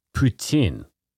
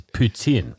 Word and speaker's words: poutine: The Quebec French pronunciation of poutine. – Yeah, and the rest of us is poutine.